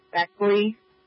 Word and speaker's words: Beckley: Can you place me at 6401 Beckley Street for an inspection? Happy B 18, which street is that? I'm sorry, repeat. The street, can you pronounce the street again? Beckley, B-E-C-K-L-E-Y.